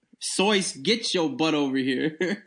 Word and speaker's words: Seuss: If you're calling him Seuss, he pronounces it Soyce.